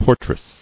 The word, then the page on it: Portress: por·tress